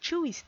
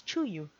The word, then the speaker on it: Tzuyu: When I first encountered Tzuyu's name, like a lot of other people that don't speak Korean, I pronounced it as Tzuyu. Then I watched some interviews and variety shows and quickly learned that it's pronounced as Tzuyu.